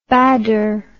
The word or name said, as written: bader